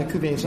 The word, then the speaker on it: cuvées: I mean, I think I'll start, but I think that, you know, we've done the cuvées on a couple of times, and that's where, you know, you're incorporating.